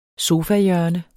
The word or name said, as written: sofahjørne